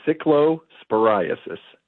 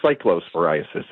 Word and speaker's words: cyclosporiasis: It is cyclosporiasis or cyclosporiasis. It's a sort of a potato-potato thing.